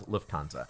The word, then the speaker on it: Lufthansa: Is that Lufthansa? What is it? Did I say it right? – Lufthansa? Yes, that's an airline.